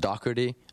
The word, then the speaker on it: Doherty: Apparently it's pronounced Doherty over there.